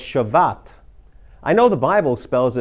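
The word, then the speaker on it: Shevat: But see how they spell it? Sabbat, right there? It's really, once you learn Hebrew, that is an SH. Yeah, so it's Shavat.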